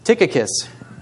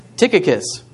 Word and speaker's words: Tychicus: Tychicus. – Tychicus.